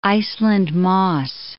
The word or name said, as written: Iceland moss